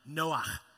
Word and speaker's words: Noah: Now the actual pronunciation is more like Noah.